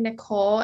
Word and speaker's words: Nicolle: My handle is I am Kylie Nicole, and my first name is spelled K-Y-L-E-E. And my middle name is N-I-C-O-L-L-E.